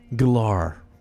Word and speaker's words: Gellar: But like, there's always those, like, when Sarah Michelle Gellar, like I like pronounced it, all of a sudden changed to Galar. And I was like, what? What, you change it? No, it's Galar.